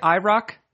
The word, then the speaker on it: IROC: Is that how you say it? IROC? – Yeah.